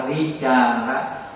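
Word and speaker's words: vicāra: Chữ này đọc là vi cha ra.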